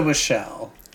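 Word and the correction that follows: Whelchel: Like a Lisa Welchel. – Welchel, yes.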